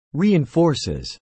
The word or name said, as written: reinforces